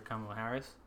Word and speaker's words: Kamala Harris: And just because I remember the very first time I was watching, it was Senator Kamala Harris.